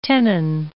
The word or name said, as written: tenon